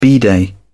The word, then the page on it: bidet: British English